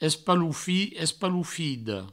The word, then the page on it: espalofit, espalofida: provençal; espaloufi; espaloufido